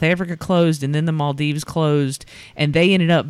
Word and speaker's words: Maldives: So, there's this couple that went on their honeymoon in the Maldives.